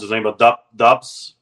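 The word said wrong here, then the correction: Dobeš: Dobish. Jakub Dobish.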